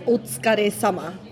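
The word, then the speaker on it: otsukare sama (お疲れ様): How did I do with that word? – Okay. I forgot to give you pronunciation. – No, I'm okay. I feel good about it. I want to encourage people who don't know how to pronounce something to give it a shot and then ask for help. – Absolutely. – So give me help. – It's otsukare sama.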